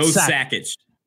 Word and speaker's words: Sakic: I said Patrick Waugh, Joe Sakic. Sakic. I'm saying it wrong now. – It's Joe Sakic.